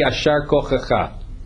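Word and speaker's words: Yasher Koach: It's really, if you were to pronounce it properly in Hebrew, it would be Yashar Kochacha. – Yashar. – Right? Yashar Kochacha, meaning may your strength continue.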